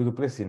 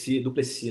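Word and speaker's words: Du Plessis: Que o Duplessis, né? Eu tava falando Duplessis, né? Mas você tem a finesse da pronúncia.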